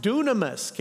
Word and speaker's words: dunamis: It sounds different than it looks, but here's the word, and it's pronounced dunamis.